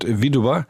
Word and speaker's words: Widua: Das lateinische Wort Widua, das D-U wird wie Dube ausgesprochen, ist die Witwe.